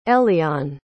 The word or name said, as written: elian